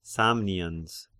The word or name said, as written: Samnians